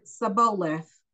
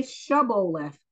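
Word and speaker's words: Shibboleth: And they said to him, say Sibboleth, And he said, Saboleth. Oh, they said, say Shaboleth. And then if somebody says Saboleth, which they couldn't pronounce it correctly, then they would seize him and kill him.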